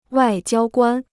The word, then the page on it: 外交官: wài jiāo guān